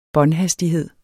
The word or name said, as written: båndhastighed